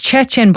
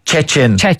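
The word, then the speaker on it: Szczecin: It's Chechen, but it's very hard. If you look at how it's written. – The Poles love Z's or Z's. So it's S-Z-C-Z-E-C-I-N. – But pronounced? – Yeah. Chechen.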